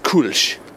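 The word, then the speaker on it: Kölsch: It's pronounced Kolsch. In America, a lot of people know it as Kolsch beer.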